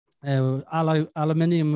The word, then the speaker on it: aluminium: I remember I was doing aluminium wheels, or aluminum, I think he called it.